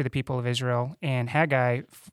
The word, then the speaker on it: Haggai: Haggai, how did you say it? – Haggai, yeah, that's how we're saying it.